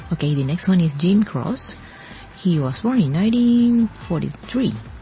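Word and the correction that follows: Croce: It's pronounced, by the way, Crochet. Crochet, mm-hmm.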